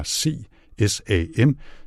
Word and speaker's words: CSAM: Det ene akronym er C SAM. Det står for Child Sexual Abuse Material, altså børneporno på dansk, og det udtales CSAM.